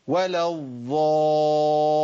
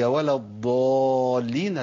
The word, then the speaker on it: الضالين: انا في ناس بسمعها بتبدل الضاد بضاء، يقولوا ولا الضالين، الضالين اللي هم الدائمين. شو المعنى الغير ازاي؟ هي ولا الضالين الذين ضلوا.